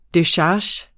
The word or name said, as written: decharge